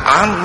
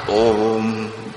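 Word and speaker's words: Om: À, có nơi đọc là ôm ôm, miền Bắc đọc là úm mani, trong Nam mình là ám mani, đọc theo phiên âm Phạn là ôm, có nghĩa là đề tỉnh.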